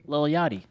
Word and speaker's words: Lil Yachty: Lil Yachty.